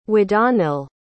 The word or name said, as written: wadanhyll